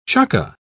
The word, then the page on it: chukka: British English